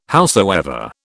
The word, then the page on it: howsoever: British English